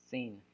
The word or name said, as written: sin